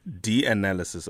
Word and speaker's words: DNAlysis: DNALysis, okay.